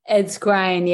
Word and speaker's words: Ed Skrein: Also, Ed Skrein. I pronounce his name? – Ed Skrein. Yeah, excuse me.